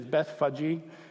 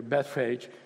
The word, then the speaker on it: Bethphage: "Now when they drew near to Jerusalem, to Bethphage," actually the pronunciation is Bethphage, but we're going to go with the English pronunciation here, Bethphage, "and Bethany at the Mount of Olives, Jesus sent 2 of his disciples.